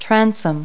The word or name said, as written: transom